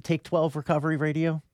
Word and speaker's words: Take 12 Recovery Radio: Is that how you pronounce it, Take 12 Recovery Radio? – Yeah, Take 12 Recovery Radio.